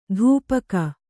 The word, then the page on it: ಧೂಪಕ: dhupaka